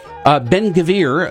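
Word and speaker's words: Ben-Gvir: Ben-Gavir, is that how you pronounce his name? – You're right.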